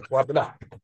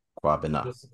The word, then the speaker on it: Kwabena: Did I say it correctly? – Yes, sir. Kwabena. – Kwabena.